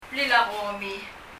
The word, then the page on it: blil a gomi: [blil ə gɔmi]